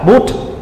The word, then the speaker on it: Buddha: Sanskrit hay là Hindi đều đọc là Buddha tại vì chữ a đó đứng ở cuối chữ được gọi là a câm nên không có đọc